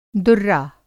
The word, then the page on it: दुर्रा: Hindi